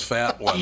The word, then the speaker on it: Fatone: How's, you know, it's so sad that that guy's name was Fat One. – Yeah. – It's Fatone. – I know it's Fatone, but it's spelled F-A-T-O-N-E.